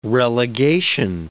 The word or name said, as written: relegation